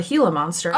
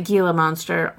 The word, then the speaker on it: Gila monster: Oh yeah, and it's also spelled with a G. And I was confused. – And people pronounce it Gila monster all the time. – Well, I've only ever heard Gila monster, and then I saw it, and I was like, I don't know what that is because my brain wasn't putting.